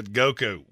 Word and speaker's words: Goku: Um, that's uh, Goku.